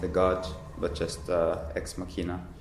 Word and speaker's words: Ex Machina: It's pronounced Ex Machina, I think, in Latin.